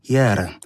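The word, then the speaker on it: Hér: Tomasz Lipa-Lipnicki, Pola Bitew i zespół Jer, Monochrom. Zespół pisany trzema literami H, E z apostrofem i R. Wymowa islandzka.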